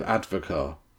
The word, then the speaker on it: advocaat: Eggnog, the drink, is made primarily of advocaat. That's the alcohol base in it. No? Were you unaware of this? – What is advocaat? – It's spelled advocat, but it's pronounced advocaat. A-D-V-O-C-A-A-T.